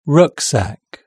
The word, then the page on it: rucksack: British English